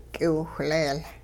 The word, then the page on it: k'iwiwhleel: hupa